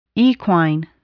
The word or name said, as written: equine